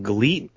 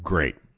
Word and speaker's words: GLEAT: Gleet? – It's spelled G-L-E-A-T, but in katakana it's pronounced great. That's how it's pronounced. It's supposed to be pronounced great, but it says Gleet.